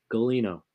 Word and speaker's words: Gulino: Gulino. – Oh, that makes sense. – Proper East Coast Italian, you know.